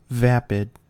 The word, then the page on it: vapid: American English